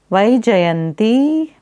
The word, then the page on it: Vaijayanti: Sanskrit